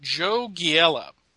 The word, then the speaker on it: Joe Giella: And it was written by Paul Kupperberg, who's written into the show before, and penciled by Kurt Schaffenberger, and inked by, I'm going to pronounce it, Joe Giella, because that's what it looks like to me. – Yeah, that's what I've heard before as well.